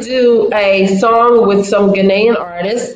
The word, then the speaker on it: Ghanaian: Ghanaian.